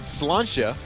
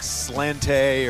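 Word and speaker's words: sláinte: and somebody said slancha, and apparently that is what, if you've ever seen S-L-A-I-N-T-E, you know, I thought it was like slante or slainte, I didn't know how to say it.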